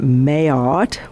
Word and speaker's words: Maillard: Because we want that maillard, it's pronounced maillard, although it's spelled differently.